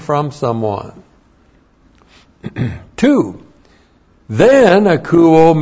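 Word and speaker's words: Akouo (ἀκούω): A-K-O-U long O. Akouo.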